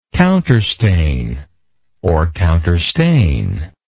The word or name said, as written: counterstain